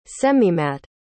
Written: semimatte

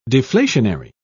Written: deflationary

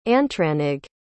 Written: antranig

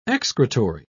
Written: excretory

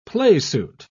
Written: playsuit

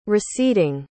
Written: receding

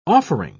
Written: offering

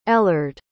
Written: ellard

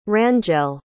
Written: Rangel

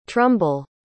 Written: trumble

